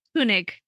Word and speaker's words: Koenig: Koenig. Did I say it right? – Yes, you did.